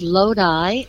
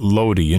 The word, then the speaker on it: Lodi: Well, I'm glad you said that right, because you know what I used to call that? I used to call it Lodi until someone corrected me.